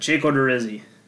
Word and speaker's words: Odorizzi: So it's Sanchez, Annabelle Sanchez, and Jake Odorizzi. Jake Odorizzi. Jake Odorizzi. Odorizzi. Odorizzi. All right, I butchered that. Jake Odorizzi. There we go. Hopefully that's it. I like this one a lot, but I'm going to talk about Annabelle Sanchez first.